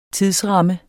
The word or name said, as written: tidsramme